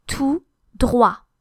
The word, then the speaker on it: tout droit: The word for straight ahead is tout droit. And they're both words are spelled with a final T, which is not pronounced because it's final. Tout, T-O-U-T, and then droit, D-R-O-I-T. Tout droit, straight ahead.